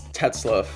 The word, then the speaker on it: Tetzlaff: It's Tetzlaff. – Tetzlaff. – It's almost like it's... Yeah, almost... It's spelled T-E-T-Z-L-A-F-F, but it's pronounced like L-U-F-F.